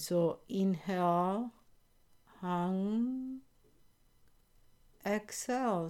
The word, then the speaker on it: hong: You write hung, H-O-N-G, but you pronounce U-N-G. And sa, S-A-U, sa.